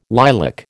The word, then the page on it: lilac: American English